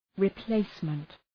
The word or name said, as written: replacement